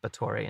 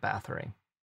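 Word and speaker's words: Báthory: Which is why it's a shame it's pronounced Bottori instead of Bathory.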